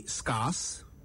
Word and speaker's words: scarce: How do you pronounce this word? S-C-A-R-C-E. Did you say scarce? I know, many people say that. It's actually scarce.